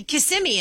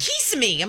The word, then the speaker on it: Kissimmee: She's the one who's confused me, and I don't know how to say Kissimmee anymore. Yeah, yeah. I think... Did I just say it right? – You said it right. – Yeah, because she's like, Kissimmee. I'm like, that's not how you say it.